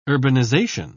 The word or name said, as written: urbanization